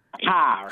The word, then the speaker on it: Haar: Have I got your name pronounced right? – Haar. – Haar. Right, good.